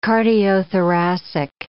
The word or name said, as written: cardiothoracic